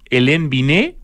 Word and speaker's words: Hélène Binet: También hiciste clases con Hélène Binet,